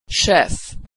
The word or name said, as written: chef